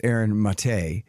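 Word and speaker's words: Aaron Maté: Max and Aaron, Aaron Mate, of course. I never say it right. It's Aaron Mate.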